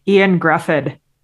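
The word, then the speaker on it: Ioan Gruffudd: ian Gruffudd i believe you're fantastic nailed yes um, he's like welsh so maybe it's yeah.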